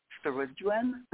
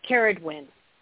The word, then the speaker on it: Ceridwen: And I know I do not say this goddess's name correctly. I say Ceridwen. – Okay. – But you pronounce it probably the correct way. How did you say her name? – Ceridwen.